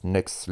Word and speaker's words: Knucks: Donc si vous avez aimé, ça s'écrit K-N-U-C-K-S, K-N-U-C-K-S, Nux.